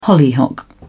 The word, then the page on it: hollyhock: British English